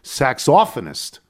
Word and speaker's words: saxophonist: I applaud the young saxophonist, British pronunciation, saxophonist.